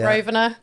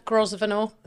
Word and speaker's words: Grosvenor: Is that how you say your second name? – Yeah, you can say it like that. Yeah. Grosvenor, Grosvenor. Yeah.